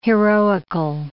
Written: heroical

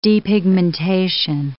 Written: depigmentation

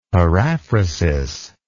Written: paraphrasis

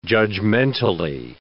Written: judgmentally

